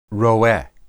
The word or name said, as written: roeh